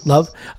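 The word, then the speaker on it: Löw: Love.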